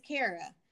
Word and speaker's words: Kara: And for whatever reason, people have the hardest time pronouncing my name. I'll say, my name is Kara. I get Karen. I get Kara. I get Kara. Kira. For the life of me, I'm like, let's sound it out, people.